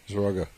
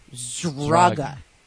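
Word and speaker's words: Szraga: His last name is spelled S-Z-R. How the heck, S-Z-R? A-G-A. How the heck do you pronounce that? – Zraga. – Zraga.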